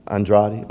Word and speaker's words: Andrade: You know, I thought it was hard on me, but what was amazing is that Eric Whitaker and Cade Andre, how you say it? Andrade.